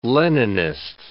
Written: Leninist

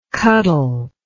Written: cuttle

